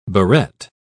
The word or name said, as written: barrette